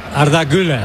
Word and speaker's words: Arda Güler: Arda Güler.